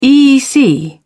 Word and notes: eec: British English

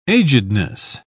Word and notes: agedness: British English

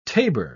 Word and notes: tabour: British English

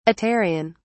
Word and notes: aterian: British English

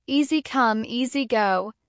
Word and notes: easy come easy go: American English

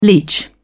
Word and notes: leech: British English

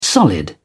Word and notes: solid: British English

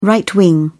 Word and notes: right-wing: British English

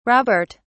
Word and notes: robert: British English

